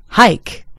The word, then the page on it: hike: British English